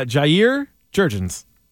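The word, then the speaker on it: Jair Jurrjens: Jair Jurgens. – He got it. – Jair Jurgens. – Is that how you say it? – Yeah, Jair Jurgens.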